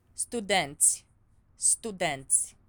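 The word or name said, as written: studenții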